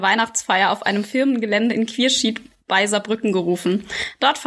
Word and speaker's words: Quierschied: Also Quierschied ähm, spricht man das mhm. aus.